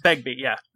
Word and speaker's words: Begbie: The one that absolutely steals the show, and he does that in everything he's in, I wanted to say his name is Bigby, he plays Bigby. It's Robert Carlyle. Begbie.